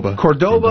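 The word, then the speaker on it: Córdoba: This one's coming to us from Cordoba. – Cordoba. – I said it correctly the second time.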